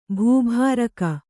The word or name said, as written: ಭೂಭಾರಕ